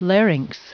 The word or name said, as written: larynx